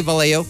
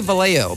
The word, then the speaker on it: Vallejo: It's Vallejo. And I'm like, wince. Oh, I love you, man. It's all good. But we say Vallejo. Why do we say Vallejo? Because it's just how we say it.